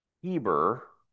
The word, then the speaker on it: Eber: He had a son, Eber, which is also often pronounced Heber.